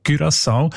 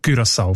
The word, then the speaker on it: Curaçao: Das sind eben die Aussprachendatenbank, die haben nämlich gerade letzten Dezember die Aussprachempfehlung geändert von Curaçao auf Curaçao, weil sie beziehen sich dort auf eine Aussprachenduden und auf das digitale Wörterbuch von der deutschen Sprache, wo beide eben Curaçao vorschreiben.